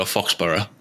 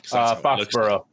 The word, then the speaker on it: Foxborough: I mean, I would normally go Foxborough. – Foxborough. – Foxborough, yeah.